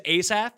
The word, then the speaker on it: Asaf: No, it's more Asaf. – It is Asaf. That is correct. – Like Ace, like the card, and then Saf. So Asaf.